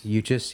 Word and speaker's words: Uchis: Uchis, yeah.